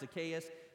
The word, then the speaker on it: Zacchaeus: And I'm sorry, I go back and forth from Zacchaeus and Zacchaeus. It's pronounced Zacchaeus, but I grew up saying Zacchaeus was a wee little man. I can't get it out of my mind.